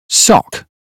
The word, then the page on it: sock: British English